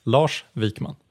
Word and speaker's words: Lars Wikman: Lars Wikman.